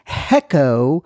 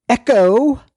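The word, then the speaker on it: Hecho: But my friend has counseled me and advised that the word Heco is not pronounced Heco. It is Echo in Tejas. The H is silent.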